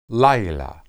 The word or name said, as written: laylah